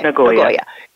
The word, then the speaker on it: Nagoya: Some people just say Nagoya. – Mm-hmm. Nagoya? Is it right? Okay. – Nagoya.